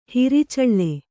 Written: ಹೊರಚಾಳಿ

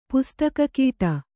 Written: ಪುಸ್ತಕಕೀಟ

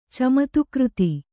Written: ಚಮತ್ಕಾರಿತ

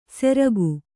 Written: ಸೆರ್ಕು

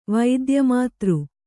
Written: ವೈದ್ಯಮಾತೃ

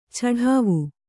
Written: ಚಡಾವು

